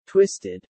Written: twisted

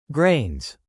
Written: grains

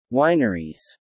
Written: Wineries

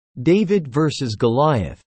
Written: David versus Goliath

